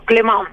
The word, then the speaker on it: Clement: Primero le voy a preguntar a Cristín si pronuncié bien su apellido. – Rodrigo, bueno, mucho gusto. Y sí, increíblemente creo que sos de las pocas personas que han pronunciado bien mi apellido, Clemón.